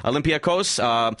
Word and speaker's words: Olympiakos: He's going to be playing in the EuroLeague with Olympiakos, that's how you say it. Olympiakos, which is a really good, successful EuroLeague team.